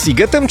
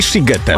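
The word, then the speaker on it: Sziget: Czy możemy ustalić, czy ten shiget, mimo że jest przez SZ, to jednak jest sigetem czy shigetem? – Oczywiście, ponieważ w języku węgierskim szy czyta się jak sy, a aha, sy czyta się jak szy.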